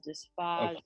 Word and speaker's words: dysphagia: So I'm just keeping the vowel the same, you know, dysphagia, phagito. – That is the correct way to say it.